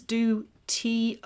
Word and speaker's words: du Toit: And Alison actually recalls that Franz de Toit, or twat. You know, it's pronounced Franz du Toit.